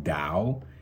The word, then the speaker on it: Tao: It's spelled T-A-O, but it's pronounced Dao.